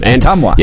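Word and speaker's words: Ottumwa: Otumwa.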